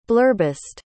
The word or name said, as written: blurbist